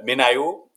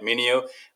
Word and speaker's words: MinIO: Uh, MinIO, uh, some people call it Minio. The official pronunciation is MinIO.